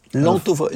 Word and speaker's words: L'anthophobie: L'anthophobie, C'est ça s'écrit.